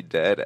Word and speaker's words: Ded: Dead, and that's spelled D-E-D.